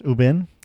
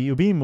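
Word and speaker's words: Ubume: I think it's pronounced Ubin. – Okay. All right. – Which actually is pretty good because you're not supposed to say the real name of the demons. – Mm-hmm. So we just mispronounce everything. – Yeah, we're fine. – Nothing will follow us home. – And Ubin, I think that's, it's U-B-U-M-E. So it's Ubin.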